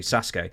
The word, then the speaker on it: Sasuke: Great I think they pronounce it Sasuke. Joey pronounced it, but Sasuke. I think it's actually Sasuke.